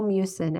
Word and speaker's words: mucin: Don't even know how to pronounce. Can you say that a little? – Snail mucin, MUCIN.